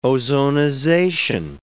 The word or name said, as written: ozonization